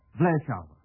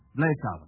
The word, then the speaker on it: Bleeschauwer: Well, I'll spell it. B-L-E-E-S-C-H-A-U-W-E-R. Bleschauer. – Sounds like Levant to me. – That's played by Levant, yes. Yeah. Bleschauer.